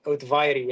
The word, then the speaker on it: Ohtuvayre: Ohtuvayre, yeah.